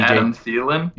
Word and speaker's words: Thielen: And DJ, Adam Thielen? – Yeah, Adam Thielen, that guy.